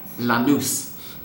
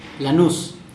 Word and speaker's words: Lanús: Lanús. – There we go. No sh sound, and the stress goes on the last syllable, and the A is short, la, not la.